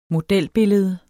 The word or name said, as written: modelbillede